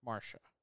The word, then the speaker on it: Marcia: Well, it's spelled M-A-R-C-I-A. – Marsha.